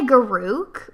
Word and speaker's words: Garruk: I mean, I say Garruk, but you can say it wrong if you want. It's fine.